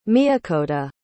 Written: miakoda

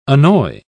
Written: annoy

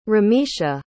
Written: Ramesha

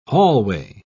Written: hallway

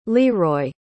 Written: leroi

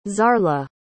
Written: zarla

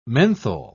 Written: menthol

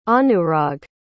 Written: anurag

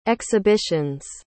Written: exhibitions

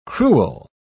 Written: crewel

